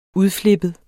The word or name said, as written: udflippet